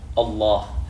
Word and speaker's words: Allah: if there is a fatha or dhamma before, you pronounce Allah with a full mouth. – Correct. – Allah. And if there's a kasra before, it becomes Bismillah.